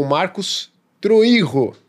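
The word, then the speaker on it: Marcos Troyjo: E para falar mais sobre isso, nós estamos hoje aqui com Marcos Troijo, tá? Vocês provavelmente já ouviram a pronúncia de outras maneiras. Mas, Marcos, está correto? Marcos Troijo? – Tá certinho, uma bela pronúncia, parabéns.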